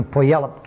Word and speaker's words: Puyallup: If you wish to have an overall set of tapes in one complete set, then you ask for the tapes from the Puyallup camp. It's spelled P-U-Y-A-L-L-U-P.